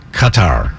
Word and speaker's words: Qatar: So the real way is Qatar. – Qatar.